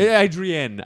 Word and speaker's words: Adrienne: Who's Adrian Barbeau? – And then I had to look her up. – It's a woman. – Adrienne. Oh, sorry, I didn't give it the right pronunciation. Okay. Adrienne Barbeau.